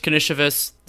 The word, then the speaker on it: Karnisovas: Officially, Arturas Knishevas, is that the correct pronunciation? That's how I've had it in my head. – Yes, I believe that is correct.